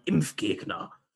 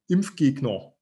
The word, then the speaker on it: Impfgegner: Oh yes, that beautiful, melodious German word, Impfgegner. You can also pronounce it as if you came from the east of Germany, Impfgegner.